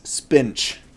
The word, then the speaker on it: Spinch: Katie, I want you to know the actual name of this game is Spinch. – That's not correct. – S-P-I-N-C-H. – So you were pronouncing it way wrong.